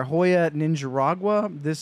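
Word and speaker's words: Joya de Nicaragua: So I went with our Hoya Ninja Ragua.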